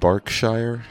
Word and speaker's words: Berkshire: and so I know that that word is Berkshire, not Berkshire.